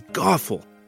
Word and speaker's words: Gothel: Her name was Gothel, which rhymes with awful, and she was awful.